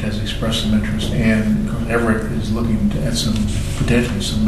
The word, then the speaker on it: Everett: Everett.